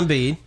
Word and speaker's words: Embiid: Embiid. Is that how you pronounce it? – Joel Embiid. – Yeah.